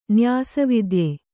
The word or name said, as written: ನ್ಯಾಸವಿದ್ಯೆ